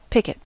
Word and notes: picket: American English

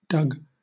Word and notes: dug: British English

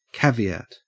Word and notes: caveat: Australian English

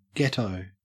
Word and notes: ghetto: Australian English

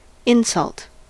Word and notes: insult: American English

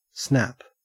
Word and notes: snap: Australian English

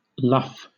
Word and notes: luff: British English

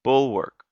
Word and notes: bulwark: American English

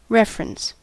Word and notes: reference: American English

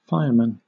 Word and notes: firemen: British English

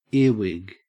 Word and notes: earwig: Australian English

